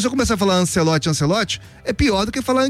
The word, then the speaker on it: Ancelotti: a pronúncia correta seria Ancelotti e não Ancelotti.